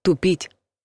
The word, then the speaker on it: тупить: Ударение в глаголе ставят так — тупить. И в случае, когда хотим сделать что-то менее острым, затупить нож, например, и в случае, когда плохо думаем и ошибаемся. Но мы должны переставить ударение на «у», если имеем в виду «потупить взгляд». Не перепутайте.